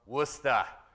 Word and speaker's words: Worcester: I'm sorry, but my wife Julie and our four kids also extend their love to you from Worcester. You just got to drop the R's. That's how you say it.